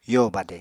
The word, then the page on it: yo badè: apatani